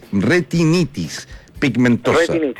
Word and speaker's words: retinitis pigmentosa: Sí, permíteme una corrección, porque yo pronuncié mal la enfermedad, es retinitis pigmentosa. – Retinitis pigmentosa. Pigmentosa.